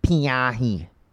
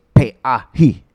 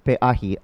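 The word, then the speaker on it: Pe'ahi: He said, "It's not Pei Ahi." And he was making fun of me, which I deserved. He said, "It's Pei Ahi."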